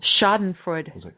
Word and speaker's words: Schadenfreude: Looked up the pronunciation. – Schadenfreude. – Okay. – Schadenfreude. – Okay, now we got our pronunciation lesson for the day.